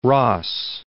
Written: ross

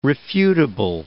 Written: refutable

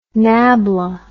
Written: nabla

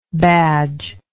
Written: badgeless

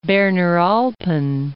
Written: Berner Alpen

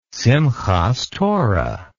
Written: simchath torah

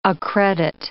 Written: accredit

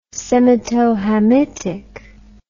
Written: semitohamitic

